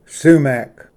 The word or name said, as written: ᏆᎶᎦ